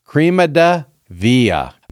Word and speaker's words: crema de vie: And it's a bit of an odd name because crema is Spanish and V is French, so it's Spanish and French, but the Cubans will pronounce it crema de vía.